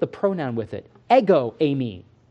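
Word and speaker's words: ami (εἰμί): Again, the Greek New Testament here, this is a verb that means I am. It's pronounced ami. It means I am all by itself.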